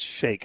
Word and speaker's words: Shayk: Yeah, it's Shayk is how you pronounce it.